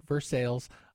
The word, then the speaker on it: Versailles: And it's spelled Versailles. Don't come at me in the comments. I know, but that's how we pronounce it, Versailles.